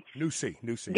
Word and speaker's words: NuSi: Yeah, they insist that it's pronounced NuSi. Okay, so I'm assuming that they want you to use the S-E-E and think that this is a new way of looking at nutrition.